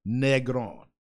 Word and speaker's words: Negron: 2-0, Mr. Denzel Million Dollar Smile Negron.